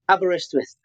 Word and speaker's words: Aberystwyth: It's Aberystwyth. How do you say that? – Aberystwyth.